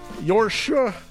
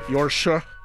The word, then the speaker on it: Jordsjø: And we started the program with a group from Oslo called Jordsjø. Pardon my Norwegian. I think that was a pretty good pronunciation, though. It's spelled J-O-R-D-S-J, and then the O with a slash through it. Jordsjø.